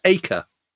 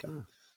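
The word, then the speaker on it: Eaker: Apparently pronounced Eker. – Really? – We Brits want to call him Eker, but apparently it's Eker.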